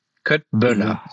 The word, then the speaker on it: Köttbullar: Ja, und dort habe ich gelernt und ich prahle bei jedem IKEA-Besuch mit meinem Wissen, dass ein K wie ein SCH ausgesprochen wird. – Ein K wie ein, ja, ja, ja. Ja, ja. – Indem ich selbstverständlich Köttbullar bestelle. Köttböller, so heißt das richtig.